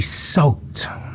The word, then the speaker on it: Sowt: It's called simply Salt. Now, Salt is spelled S-O-W-T with a umlaut over the O.